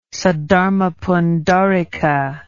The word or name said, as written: saddharmapundarika